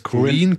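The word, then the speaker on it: Korine: Corinne, Corinne, Corinne.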